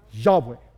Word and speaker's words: Yahweh: And it's pronounced, does anybody know? It's pronounced Yahweh.